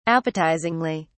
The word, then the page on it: appetizingly: British English